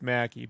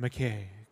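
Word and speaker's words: Mackey: It's Mackie, but here they pronounce it McKay.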